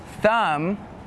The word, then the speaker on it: thumb: And some examples with a silent B are lamb, thumb, and doubt.